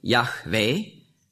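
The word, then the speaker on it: Jahwe: Wenn man das Alte Testament in der Synagoge vorliest und den Eigenname Gottes, J-H-W-H geschrieben, korrekte Aussprache, Yahweh, der Ewigseiende, der Unwandelbare.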